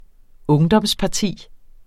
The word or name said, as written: ungdomsparti